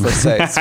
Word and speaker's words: Versace: It's pronounced Versace, Mark.